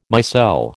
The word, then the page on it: micelle: American English